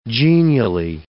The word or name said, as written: genially